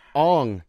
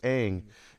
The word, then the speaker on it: Aang: Ong, but in the show it's pronounced Aang.